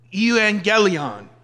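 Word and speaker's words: evangelion (εὐαγγέλιον): And that is where you get this Greek word evangelion. The V comes in just with how you say it.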